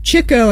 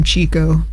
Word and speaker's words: Chico: But anyway, that was Chico, I think, is how it's supposed to be pronounced, even though the announcer called him Chico, but...